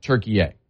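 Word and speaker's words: Türkiye: They're only now saying, by the way, we actually would prefer it if you said Turkey A.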